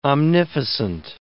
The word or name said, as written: omnificent